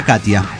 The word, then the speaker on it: Katja: Se escribe Katja, y se pronuncia Katia, perfectamente.